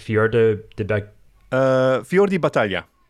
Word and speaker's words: Fior di Battaglia: I dug around my copy of Fior di Battaglia. – You would be able to say that. That doesn't surprise me at all. – I think the GL, then the G is silent.